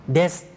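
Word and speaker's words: เด ส (です): เดสกะครับเดสออกเสียงรวบยาวอย่างที่บอกไปเมื่อสักครู่นะครับเดส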